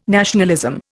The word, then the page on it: nationalism: Indian